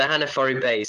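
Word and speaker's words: Hanifaru: And there's something that I actually want to know about, is a trip to, and I don't know if I'm saying this right, Hanafaru Bay? – Yes, the Hanafaru Bay.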